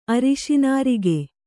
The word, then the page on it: ಅರಸುನರಕ: arasunaraka